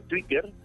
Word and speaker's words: Twitter: Eh, Twitter, porque la expresión en español es que la eh, T se pronuncia de esa manera, Twitter. Eh, decir Twitter sería estar utilizando una fonética inglesa para una palabra se puede pronunciar en español.